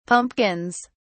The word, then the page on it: pumpkins: British English